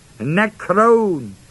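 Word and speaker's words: nekron (νεκρῶν): From the dead is the word, are the words ek, e-ek, out from, nekron, n-e-k-r, long o-n, off of which we get our English word necrology and all of that, meaning dead.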